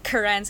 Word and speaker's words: Karen: It's spelt like Karen, like the men. – Yes. Like my sister's name, actually. – Yeah. – Yeah. – But it's actually pronounced like Karen, so there's like an emphasis on the second syllable.